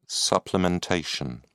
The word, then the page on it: supplementation: British English